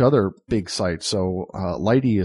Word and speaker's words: lighttpd: And then there's another one that runs on Linux called Lighty, and it's actually L-I-G-H-T-T-P-D. It's like light plus HTTPD is Lighty. It's, that's how you pronounce it, Lighty.